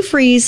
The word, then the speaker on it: Freeze: Sue Freeze, spelled like fries, one word, .com.